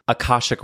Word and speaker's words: Akashic: Can you tell me, first of all, you say that you offer Akashic Records.